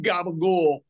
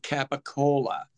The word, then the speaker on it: capicola: And over the years, as things got mispronounced, because if you go order a sandwich in New York, what people claim to be Italian is not Italian. Mutarella and some gabagool is mozzarella and capicola.